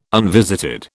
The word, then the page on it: unvisited: British English